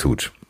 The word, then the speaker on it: Hooch: Äh, heißt also doch Hutch, oder? Heißt es Hooch? – Der heißt Hooch.